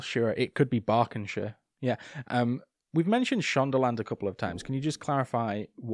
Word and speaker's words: Berkshire: Just Berkshire? Is that how you say it? – You never say Shire.